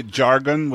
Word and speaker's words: Jargon: So you say Jargon with like a hard J? – Yes, I pronounce it Jargon.